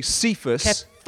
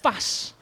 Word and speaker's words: Kephas: So often it's pronounced, you'll see Cephas. – Kephas. – The Greek Aramaic would be Kephas or Kepa in the Aramaic.